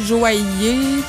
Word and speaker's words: joaillier: Les finales des mots en IE ou IR, comme dans quincailler, joaillier, ceux qui font des bijoux, là. – Ouais, ouais. – Bon, dans le fond, avant, il y avait ça finissait I-L-L-I-E-R, mais on prononce pas le dernier I. – Joaillier. – I-L-L-I-E-R.